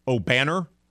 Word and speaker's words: Obanor: Obanor is how we pronounce that.